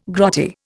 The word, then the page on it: grotty: Indian